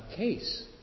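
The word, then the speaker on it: kesu: So this bell, the case, it's called, which is spelled K-E-S-U. U's are sometimes silent in Japanese.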